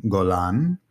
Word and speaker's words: Golan: First name, Golan.